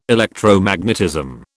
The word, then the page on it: Electromagnetism: British English